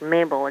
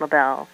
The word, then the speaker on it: Mabel: Usually when people ask me, I just say, well, my first name is Mabel. It's exactly like Mabel, except I pronounce it Mabel.